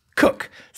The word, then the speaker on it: Coke: So the person who built this hall is called Thomas Cook, spelt Coke, but pronounced Cook.